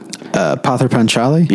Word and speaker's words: Pather Panchali: Pather Panchali? – Yeah.